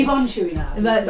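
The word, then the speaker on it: Yvon Chouinard: My friend Yves Chouinard. – Yes, exactly. – Yvonne Chouinard.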